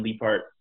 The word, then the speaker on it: Leaphart: It's just Evan Liepart. My last name is spelled L-E-A-P-H-A-R-T.